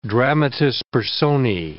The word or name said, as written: dramatis personae